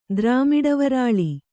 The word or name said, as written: ದ್ರಾಮಿಡವರಾಳಿ